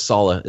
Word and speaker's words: Saleh: But then even aside from that, just And this is where a question, and by the way, it's Robert Saleh is how you pronounce his name.